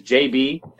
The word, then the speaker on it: Jabee: But yeah, so the artist, his name is JB, and it's spelled J-A-B-E-E.